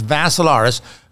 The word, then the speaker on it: Vassilaris: The last name is Vassilaris, rhymes with Assilaris, but you add a V.